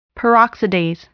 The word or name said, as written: peroxidase